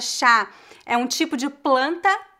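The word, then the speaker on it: chá (茶): É chá, chá. O CH nós pronunciamos como se fosse TCH e a gente sobe um pouquinho a entonação.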